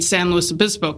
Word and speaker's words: San Luis Obispo: And at that time, my parents had purchased a second home in San Luis Obispo, California. So I, and that's once I still can't pronounce that. – San Luis Obispo.